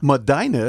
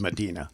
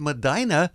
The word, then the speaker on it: Medina: You said it's a long I. – Yeah. – Medina is how he pronounced it. Said it. – It's a good thing you made that note, because I would have said Medina. – Yeah. – But he said Medina, so that he must know where he lives.